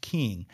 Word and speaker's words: Kueng: King, spelled K-U-E-N-G, but pronounced King, they arrived with their body cameras activated and running.